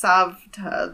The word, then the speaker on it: Savta: Also, it's Savta. The V is before the T.